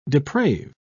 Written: deprave

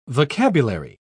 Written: vocabulary

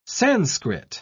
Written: sanskrit

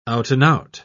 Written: out-and-out

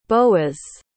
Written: boas

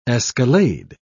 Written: escalade

